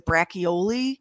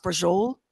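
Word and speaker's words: braciole: It looks like brachioli. – Yeah. – But it's pronounced brajol.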